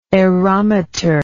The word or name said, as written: aerometric